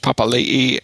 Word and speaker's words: Papali'i: Josh Papali'i, or is that? Yeah, that's the right pronunciation.